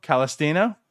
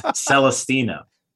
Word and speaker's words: Celestino: Like Nick Gordon with a 20.4 war and then fourth in war, Gilberto Calistena with the 10.6 war. You can't even say his name right. – Exactly. – Celestino.